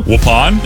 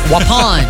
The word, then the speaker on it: Waupun: It sounds like Grey Poupon. That's how I remember it. Waupun.